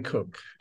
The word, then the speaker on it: Kook: Can you explain Avram Yitzchak Cohen Kook.